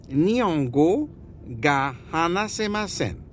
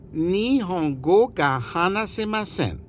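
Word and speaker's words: Nihongo gahanasemasen (日本語が話せません): Jetzt sag Nihongo ga hanasemasen. – Nihongo Hagamabaga. – Nihongo gahanasemasen. Und immer an die Aussprache denken. Immer als wenn du lächelst. Von einem Ohr zum anderen. Und alles 2 Oktaven höher.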